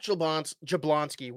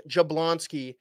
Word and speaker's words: Jablonski: Jack Jablonski. Wow, I butchered that. Jack Jablonski, who everyone in the state of Minnesota knows, obviously, for his, you know, horrific accident.